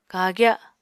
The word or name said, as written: kagya